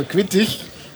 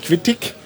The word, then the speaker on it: quittig: Äh, also mit IG am Ende, was man ja in deutscher Bühnenaussprache ich ausspricht, also quittig, also nicht quittig, sondern quittig.